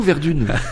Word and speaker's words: Verdun: On dit Verdun ou Verdune? – On dit Verdun, effectivement.